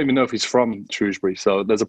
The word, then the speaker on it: Shrewsbury: The main thing that's Joe's clear up for me is it's pronounced Shrewsbury and not Shrewsbury, as I've been saying it this whole time.